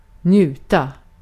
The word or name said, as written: haz almak